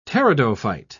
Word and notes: pteridophyte: British English

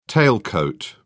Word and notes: tailcoat: British English

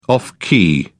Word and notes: off key: British English

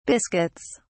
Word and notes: biscuits: British English

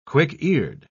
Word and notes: quick-eared: British English

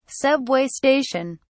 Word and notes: subway station: British English

